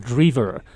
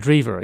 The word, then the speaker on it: Drever: First of all, my last name is spelled Drever. – Drever. – Pronounced Drever, yes.